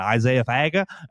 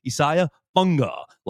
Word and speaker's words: Isaiah Funga: I did get re-educated on how to say his name this past spring because I was saying Isaiah Faga. No, it's Isaiah Funga, like Tunga Vailoa.